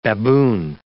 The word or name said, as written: baboon